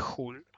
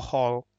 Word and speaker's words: Hull: Options, Futures and Other Derivatives de Hull, o Hall, como lo pronuncian ustedes.